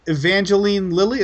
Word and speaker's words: Evangeline Lilly: And Evangeline Lilly, is that how you say her name? – Yeah.